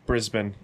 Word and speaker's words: Brisbane: Brisbane, yes.